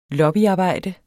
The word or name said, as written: lobbyarbejde